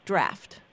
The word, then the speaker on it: draught: And anytime you see it spelled D-R-A-U-G-H-T, that's British. Although they always pronounce it draft.